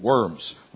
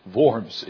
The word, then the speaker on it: Worms: Better pronunciation than Worms. Worms in Germany.